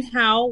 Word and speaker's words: Howe: Brian Howie. I don't know if it's spelled Howie or How, but H-O-W-E. – Yeah, it's How. – Yeah, it's How. – How? – Yeah, okay. It's How over there, yeah.